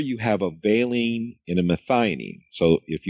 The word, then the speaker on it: valine: Now, what this means is that molecule that is breaking down the catecholamines is made up of, was it valine? Well, how do you say that, Laura? – Valine. Mm-hmm. – Valine.